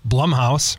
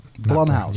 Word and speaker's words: Blumhouse: Blumhouse, which is how you say that. – Blumhouse. – Yes. Okay. You say it like plum.